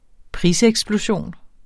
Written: priseksplosion

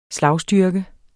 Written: SLAGSTYRKE

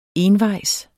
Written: envejs

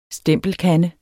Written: STEMPELKANDE

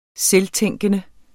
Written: selvtænkende

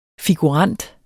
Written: figurant

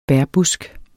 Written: bærbusk

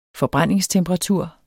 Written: forbrændingstemperatur

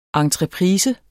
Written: ENTREPRISE

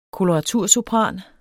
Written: koloratursopran

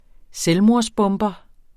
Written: selvmordsbomber

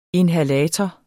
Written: inhalator

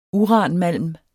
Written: uranmalm

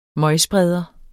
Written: møgspreder

